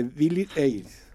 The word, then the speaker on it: Billie Eilish: Bad Bunny de Billie Eilish.